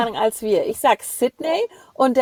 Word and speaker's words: Sydney: sondern es ist ein I. – Sydney. – Sydney. – Ja. Mehr ein I, mehr die Ja. – Betonung auf einem längeren I.